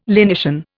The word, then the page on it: lenition: Indian